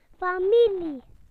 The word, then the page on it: family: British English